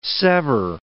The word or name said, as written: sever